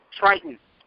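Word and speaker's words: Tritón: Anyways, apparently Triton is an actual legitimate Mexican luchador. – Triton. – No, Triton. It's the Spanish pronunciation.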